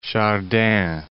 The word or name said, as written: Chardin